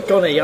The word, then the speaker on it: Gonny: Is it Gonny or? – Gonny. I was just rhyming with Johnny, you see.